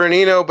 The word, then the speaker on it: Annino: Almost. Almost. It's Christopher. It's Christopher Annino, but it's all right.